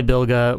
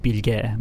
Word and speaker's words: Bilge: It's anything is fine. I go by Bilge when I'm in the U.S. Oh. And in Turkey, it's pronounced Bilge, but...